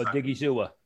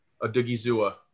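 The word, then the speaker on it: Odighizuwa: I don't know how to pronounce his last name, so I'm just Odigi Zua. – Odigi Zua. – There you go.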